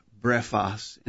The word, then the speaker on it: brephos: Spelled with English characters, B-R-E-P-H-O-S, brephos.